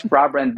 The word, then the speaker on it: Hrabren: My name is Rabren Bankov, and that literally means a brave bank in my native language.